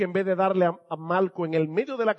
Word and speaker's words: Malco: y no estoy pronunciando Marco mal, es que se llamaba Malco con L.